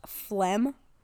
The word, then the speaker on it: phlegm: P-H-L-E-G-M. Like, listen, you could have just spelled it F-L-E-M, but no, you had to make it worse.